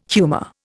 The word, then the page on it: humour: Indian